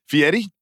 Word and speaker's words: Fieri: Fieri? – Yes.